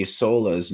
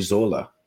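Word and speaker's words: Sölle: I've mentioned this before, and I've just found out I've been pronouncing Dorothy Sola's name wrong. It's Dorothy Zola, because of Europeans and their funny consonant sounds.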